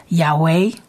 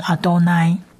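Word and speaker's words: 亚 威 (יהוה): 再来最后一个字，我们在一开始的时候也教学过，就是它是亚威，我们通常是念成亚威，但是它是四字神明，它是特别被念成亚威，或者有一些犹太人念成阿多奈。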